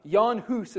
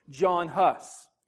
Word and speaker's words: Jan Hus: Jan Hus is how they pronounce it, but in English we usually say John Hus.